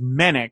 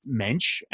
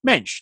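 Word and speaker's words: Moench: And then Max Romero followed up, and he says, according to a pronunciation guide at a 1984 Marvel Age, Mensch is pronounced Mensch. So I know that's very confusing because I just said it a couple different ways. I always heard it as Menick, but according to Max's comment that it's pronounced Mensch, Marvel Age presumably knows the right way to say it. So going forward, I will try to remember to say his name as Doug Mensch, not Doug Menick.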